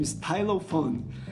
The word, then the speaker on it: stylophone: Eu não sei exatamente como pronuncia, como se pronuncia, mas presumo que seja stylophone. Isso mesmo, stylophone. Uh, Escreve-se S-T-Y-L-O-P-H-O-N-E.